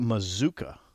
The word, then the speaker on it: Mazuka: So today, Micah Mazuka mm-hmm. rhymes with bazooka. – Doesn't look like it if you look at how it's spelled, though. – No. – Because it looks like it should be pronounced Mascua.